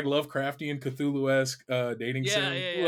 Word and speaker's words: Cthulhu-esque: Oh, yeah, Cthulhu-esque.